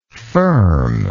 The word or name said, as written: firmness